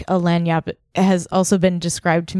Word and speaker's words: Lagniappe: Lagniappe.